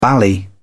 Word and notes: bally: British English

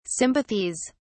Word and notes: sympathies: British English

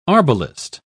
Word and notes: arbalist: British English

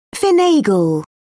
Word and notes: finagle: British English

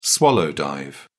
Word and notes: swallow dive: British English

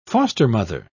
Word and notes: foster-mother: British English

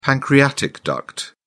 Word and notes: pancreatic duct: British English

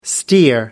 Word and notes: steer: American English

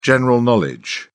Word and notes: general knowledge: British English

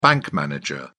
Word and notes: bank manager: British English

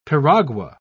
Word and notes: piragua: British English

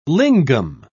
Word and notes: lingam: British English

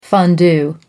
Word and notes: fondue: American English

